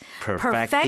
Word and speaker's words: perfect: So the accent is on the first right. – syllable. Perfect. Here, it's a verb, so it follows the second syllable. – Okay. – Perfect. – Perfect the eyelids alone.